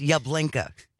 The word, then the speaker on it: Yablinka: Use Yablinka, and it's spelled like Yahblinkah.